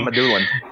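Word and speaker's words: Mukhamadullin: And then Shakir Muka Hamadoulin. Yeah, pronounced it better than me. – Muka Hamadoulin. – Yes. – Muka Hamadoulin. Muka Hamadoulin.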